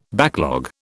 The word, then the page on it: backlog: British English